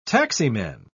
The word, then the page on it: taximan: British English